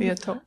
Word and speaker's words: Liautaud: Finally, and sticking with names I can't pronounce, James Liotaud, L-I-A-U-T-A-U-D, Liotaud? – Liotaud.